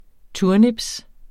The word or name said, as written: turnips